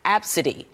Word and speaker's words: ABCDE: Well, her daughter's name is spelled A-B-C-D-E and pronounced Absidy.